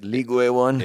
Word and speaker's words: Ligue 1: Ligue 1? – Yeah.